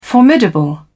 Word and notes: formidable: British English

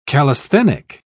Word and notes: callisthenic: British English

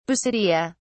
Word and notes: basidia: British English

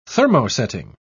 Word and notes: thermosetting: British English

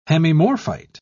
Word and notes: hemimorphite: British English

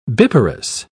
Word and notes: biparous: British English

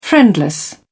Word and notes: friendless: British English

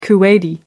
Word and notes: kuwaiti: American English

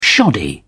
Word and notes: shoddy: British English

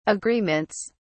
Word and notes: agreements: British English

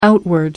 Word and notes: outward: American English

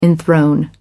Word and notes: enthrone: American English

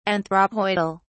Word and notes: anthropoidal: British English

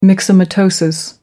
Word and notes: myxomatosis: American English

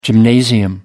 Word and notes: gymnasium: American English

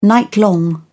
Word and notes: nightlong: British English

